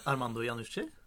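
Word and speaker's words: Armando Iannucci: Armando Janucci? – ja.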